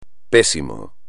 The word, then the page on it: pésimo: espagnol